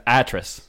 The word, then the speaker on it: Atrus: Artus or Atrus? – What it was? It was Atrus.